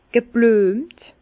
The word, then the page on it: geblüemt: Bas-Rhin; Herrlisheim